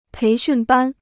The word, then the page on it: 培训班: péi xùn bān